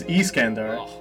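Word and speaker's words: Iskandar: Well, first off, the pronunciation is Iskander.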